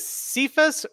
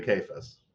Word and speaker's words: Cephas: Is it Cephas or Cephas? – I've heard it pronounced both ways. – Okay. – Ooh, that's what I always say when I don't know how to pronounce something, too. – As an academic and a doctoral, what do you say? – I usually say Cephas.